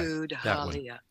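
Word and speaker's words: Tudhaliya: Pronounce it, please. – Halia. – Okay. – Tud-Halia. – Tud-Halia.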